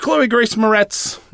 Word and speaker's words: Chloe Grace Moretz: In which Chloe Grace Moretz, am I saying that right? – Yeah.